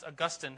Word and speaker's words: Augustine: St. Augustine, or it's even pronounced Augustine, who lived in the 400s AD, wrote all this Christian stuff.